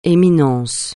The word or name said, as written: Éminence